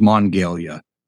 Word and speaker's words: Monongalia: Uh, so there's Monongahela and then there's Monongalia.